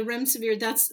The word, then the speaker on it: remdesivir: Is there remdesivir, if I pronounce that correctly? – Yeah, the remdesivir, that's one of the HIV drugs.